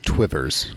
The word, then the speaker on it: Two Rivers: served for a while in Two Rivers, Wisconsin. I want to say I believe it's pronounced Twivers. – Yeah, I was trying to remember how they say it up there.